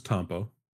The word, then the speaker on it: Tampo: So the slight correction is Tampo. – Well, see, thank you. As soon as I said it, I was like, oh, I'm pronouncing that wrong. Cool. Tampo.